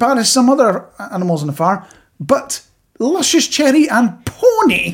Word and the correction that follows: peony: It's peony. You pronounce it peony.